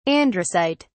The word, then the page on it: androcyte: British English